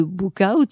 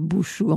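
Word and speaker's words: Bouchout: C'est donc le château de Buchaut, ainsi que le prononcent les Belges, c'est-à-dire Bouchou en français.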